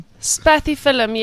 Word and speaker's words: Spathiphyllum: Is that a spatifilium? – Spathiphyllum, yes.